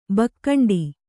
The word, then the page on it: ಭೂಖಂಡ: bhū khaṇḍa